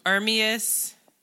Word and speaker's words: Ermias: Ermias.